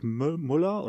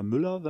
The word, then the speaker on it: Müller: Das ist der, der Derek Müller oder Müller, wenn man ihn auf Deutsch aussprechen will.